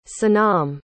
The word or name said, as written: sanam